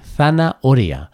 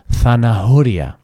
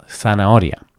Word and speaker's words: zanahoria: Así que la palabra zanahoria se pronuncia precisamente así, zanahoria, y no zanahoria. Repito, zanahoria.